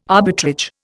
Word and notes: arbitrage: Indian

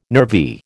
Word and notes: nervy: American English

